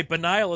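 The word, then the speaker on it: banal: And by the way, Benile is how it's pronounced, shithead.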